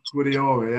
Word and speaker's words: Guardiola: Guardiola, yeah.